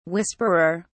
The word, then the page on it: whisperer: British English